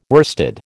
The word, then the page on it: worsted: American English